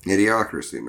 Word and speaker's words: Idiocracy: What is it, the Idiotics? I can't even pronounce it. – Idiocracy, man.